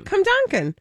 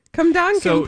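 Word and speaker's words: Cwmdonkin: It says the home of pronounce the W as a U. So you were right. – I was right. Cumdonkin. Cumdonkin Park.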